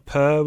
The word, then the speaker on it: Pehr: But they're name checked as Mr. Lovett and Mr. Per, P-E-H-R, Per, would you pronounce that? – Yeah.